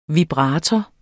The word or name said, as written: vibrator